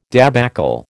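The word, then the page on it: débâcle: American English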